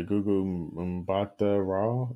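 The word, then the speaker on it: Gugu Mbatha-Raw: Oh yeah, Gugu Mbatha Raw.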